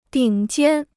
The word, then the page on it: 顶尖: dǐng jiān